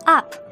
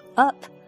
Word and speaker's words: up: 我们经常听到有人读 up up 那其实这里不是很地道的发音哦我们要读成 up up up 就像 tuck 一样 up Up, please let me button up your jacket.